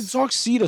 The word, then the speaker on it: Darkseid: Is it Darkseid or Side? – Darkseid. – Yes. – So it's Darkseid's symbol. – It's spelled funny, but it's Darkseid. – Yeah, it's EID.